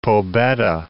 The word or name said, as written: Pobeda Peak